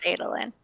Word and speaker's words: Adolin: Yep, it's Adolin.